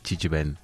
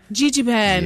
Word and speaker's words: Jijiban: We're talking all things Jijiban. Did I say it correctly? I feel like I'm saying it wrong. – No, we sure got it. Jijiban. – Jijiban. Yes. Okay, I said it correctly now.